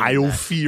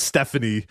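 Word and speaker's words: Aoife: Iofi or whatever, but it's like Stephanie somehow. It's like A-I-O-F-E, right. But it's pronounced it's, Stephanie. – It's A-O-I-F-E, which is like Iofi or something. But it's one of them Irish names where, yeah, it's like Jennifer or something.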